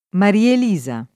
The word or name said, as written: Marielisa